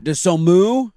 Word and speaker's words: Dosunmu: How do you say his last name? – Dosunmu? Yeah.